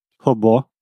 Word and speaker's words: robô: Robô, a pronúncia está... Robô. – Robô. Então, o O no final é, é fechado. – Sim, porque a gente escreve R-O-B-O, acento e chapéuzinho.